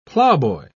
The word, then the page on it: ploughboy: British English